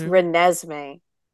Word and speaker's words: Renesmee: And we have a surprise appearance from, I'm going to say this wrong, please correct me, Treesme from the Twilight Breaking Dawn part. – It's Renesmee.